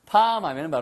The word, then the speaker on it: palm: l이 묵음이에요. 이거 팔음이 아니에요. 그래서 palm 하면 바로 예, 바로 손바닥을 이야기합니다.